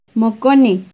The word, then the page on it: moconeuh: Náhuatl